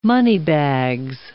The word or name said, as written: moneybags